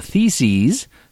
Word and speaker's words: theses: Where one of the theses, that rhymes with feces,